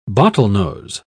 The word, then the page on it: bottlenose: British English